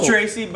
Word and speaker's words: Treacy: It's Treacy, but it's spelled differently on purpose.